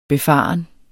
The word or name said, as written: befaren